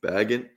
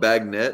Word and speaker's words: Bagent: I thought it was Bagent. – I don't know how to pronounce his name. Bagent. And then people are out there going Bagnet, and I'm like, no, that's not it. You're twisting some E's and N's.